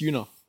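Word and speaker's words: Yuna: Just Yuno. – Like Luna, but with a Y.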